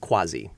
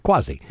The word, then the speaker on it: Quasi: I get the feeling that Harlow's has plenty of great artists coming through the Starlet Room, and this next artist, Quasi, is it Quasi or Quasi? – I've always pronounced it Quasi. – Quasi.